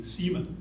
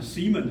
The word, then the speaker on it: Simon: 这个西门彼得的话呢，他的名字可以，事实上这个西门你要照希腊文的话是应该念成西门，所以是西门 Peter。但是呢，如果你要跟一般的美国人讲的话，你讲 Simon 的话，他听不懂你讲谁，所以他们就念成什么？他们念成 Simon Peter。